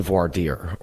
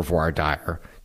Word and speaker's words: voir dire: And the first one that I want to talk about is the voir dire, or voir dire. Depends on how you say it. I don't speak French. If somebody out there speaks French, you probably can say it a little bit better than I can.